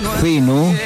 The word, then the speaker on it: Zeno: Seno se escribe con Z, ¿sí? Se pronuncia Seno, pero es con Z. Seno, como Zeno.fm, con minúscula, ¿sí?